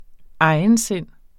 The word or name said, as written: egensind